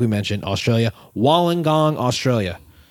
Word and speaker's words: Wollongong: But now we know how to say it, Wollongong.